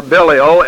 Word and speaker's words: Belial: Now the sons of Eli were sons of Belial, or Belial, as some pronounce it.